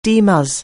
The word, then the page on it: DMus: anglais